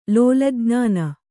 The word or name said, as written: ಲೋಲಜ್ಞಾನ